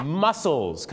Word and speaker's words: mussels: นะฮะ crab ก็คือปู crab ก็คือครีเส้นบางๆนะครับปูนิ่มนะฮะ soft shell crab นั่นเองครับต่อไปก็จะเป็นเรื่องราวของหอยนั่นเองครับน่าหอยนี่นะครับก็แน่นอน shells หรือว่า shellfish เนี่ยนะฮะก็จะมีอะไรบ้างครับที่เราทานกันประจำครับหอยแมลงผู้ mussels ครับที่แปลว่ากล้ามเนื้อเหรอครับที่จริงออกเสียงเหมือนกันแต่สะกดกันไม่เหมือนกัน